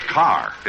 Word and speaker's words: Kerr: Jack, her name is pronounced Carr, isn't it, Deborah? – Yes, but that's all right. A lot of people make that mistake. – What do you mean, mistake? It's spelled K-E-R-R, isn't it? – Yes, but in England, it's pronounced Carr.